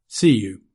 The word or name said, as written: see you